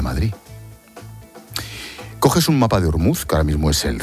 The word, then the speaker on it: Ormuz: La pronunciación en persa es algo parecido a Ormuz.